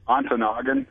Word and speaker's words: Ontonagon: How do you pronounce this town? – Ontonagon. It's in the western UP.